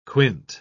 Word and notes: quint: British English

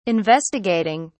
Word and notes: investigating: British English

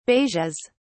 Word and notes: beiges: British English

